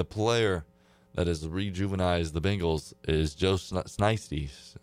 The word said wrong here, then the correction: Shiesty: Nasty, whatever they call Joe Shiesty.